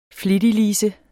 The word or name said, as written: flittig